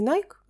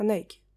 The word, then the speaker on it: Nike: У меня была история о том, что я купила Nike Nike, э, вообще правильно говорить не Nike, а Nike.